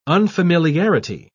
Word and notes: unfamiliarity: British English

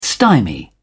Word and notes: stymie: British English

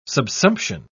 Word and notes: subsumption: British English

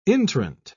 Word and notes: intrant: British English

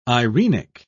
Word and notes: eirenic: British English

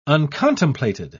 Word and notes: uncontemplated: British English

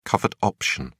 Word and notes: covered option: British English

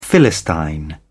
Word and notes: philistine: British English

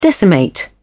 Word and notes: decimate: British English

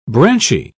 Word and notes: branchy: British English

